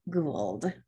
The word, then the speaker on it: Gould: Today, we have with us Leslie Gould, who is an...